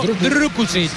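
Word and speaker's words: Drkušić: Banja Drukušić, internacional Drukušić. Esloveno. – Repítelo. – Hay que pronunciarlo Drukušić.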